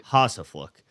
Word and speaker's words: Hosaflook: Hossafluck.